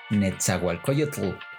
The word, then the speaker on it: Nezahualcóyotl: De hecho, ya donde está ese Estado de México es Ciudad Nezahualcóyotl. Ah, y porque lo digo con acento en inglés. Oh my god. – Sí, es Netzahualcoyotl.